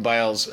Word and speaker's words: Biles: think is interesting is I think Simone Biles.